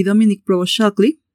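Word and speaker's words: Dominique Provost-Chalkley: Desde ahí, la química entre las actrices Kat Barrell y Dominic Provo Shockley, bla bla bla bla.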